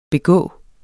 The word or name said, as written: begå